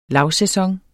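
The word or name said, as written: lavsæson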